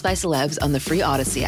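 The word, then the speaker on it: Audacy: Download the free Odyssey app. Yeah, there it is. Odyssey, A-U-D-A-C-Y. I know it's spelled funky.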